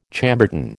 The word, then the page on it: Chambertin: American English